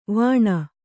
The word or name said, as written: ವಾಣಿ